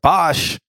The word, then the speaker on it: Bashli: It's spelled B-A-S-H-L-I. – Bashley. – I said Bashley, but they, in the beginning, they were like, Bosh.